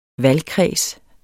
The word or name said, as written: valgkreds